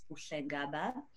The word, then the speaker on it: Buhle Ngaba: My name is Buhle Ngaba.